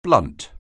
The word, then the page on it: blunt: anglais